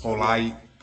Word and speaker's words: Poeltl: Poeltl.